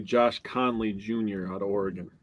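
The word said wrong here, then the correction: Conerly: You mispronounced it. – It's Connerly, bro.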